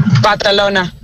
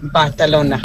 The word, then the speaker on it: Barcelona: Barcelona. You have to say it properly. Barcelona.